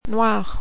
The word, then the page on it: noir: French